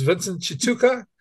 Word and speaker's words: Vincent Chituka: His name is Vincent Chituka. Did I pronounce that right, Vince? – You actually did. That's very impressive.